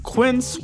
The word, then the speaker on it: Quince: Today Explained's support today comes from Quince, which rhymes with since but is spelt with a Q-U.